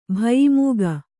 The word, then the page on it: ಭಯಿಮೂಗ: bhayi mūga